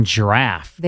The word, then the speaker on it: draught: So here, D-R-A-U-G-H-T would be drought, but there it's pronounced draft. – Okay, so we're... – I assume the announcer is pronouncing correctly. – Yeah, but therefore. It's spelled right. D-R-A-U-G-H-T. – Right, this is the British pronunciation.